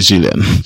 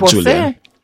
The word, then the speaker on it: Jillian: Her name is actually Jillian. – Jillian. – But I mean, people call her Julian. No, because her mother pronounced it that way. – Oh, okay. Yeah. – She was named after her father, but it's just a G sound, yes.